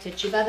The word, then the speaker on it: Tituba: I've heard it pronounced a few different ways, but I like Tituba the best, and I heard it the most.